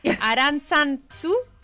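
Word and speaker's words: Arantzazu: Entonces el hombre, que era vasco, lógicamente, le dijo, y perdonadme los vascos y las vascas, Aranzazu, no sé si se pronuncia así, bien.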